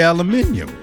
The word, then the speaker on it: aluminium: We say aluminum. You say aluminium.